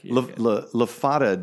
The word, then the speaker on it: LaFada: LaFada, dude, rhymes with Nevada. Mm. Like the wrong pronunciation of Nevada.